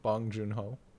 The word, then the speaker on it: Bong Joon-ho: I was just looking at other films, but how do you pronounce his name? Bong Joon-ho. – Yeah.